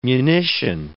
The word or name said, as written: munition